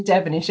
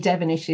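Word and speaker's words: Devonish: It's Marilyn Devenish. It's MARILYN, and Devenish is DEV for Victor, ONISH. So / Marilyn Devenish on my, you know, LinkedIn and social media channels, except for Instagram.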